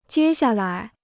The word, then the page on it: 接下来: jiē xià lái